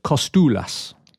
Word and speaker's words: Kostoulas: So the thing I got wrong was apparently it's a silent C at the beginning of our new 18-year-old wonder kid. Right, so... Haralampos Kostoulas.